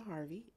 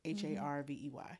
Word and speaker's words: Harvey: I am on all platforms as Christina Harvey, and my name is spelled different. So it's KHRYSTINA. And Harvey is spelled normal, HARVEY.